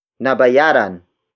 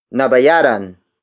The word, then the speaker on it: nabayaran: Nabayaran ang utang namin. Nabayaran ang utang namin. The first word is nabayaran. Nabayaran. Nabayaran. Nabayaran is spelled as N-A-B-A-Y-A-R-A-N.